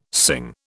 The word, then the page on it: Singh: British English